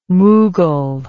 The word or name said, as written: mughal